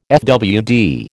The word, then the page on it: fwd: American English